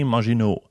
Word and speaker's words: Maginot: Ähm, und zwar Verteidigungsminister André Maginot. Das ist äh, komplett richtig ausgesprochen.